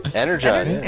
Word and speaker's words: Energon: Did I say that right? Energon? – Energon, yeah.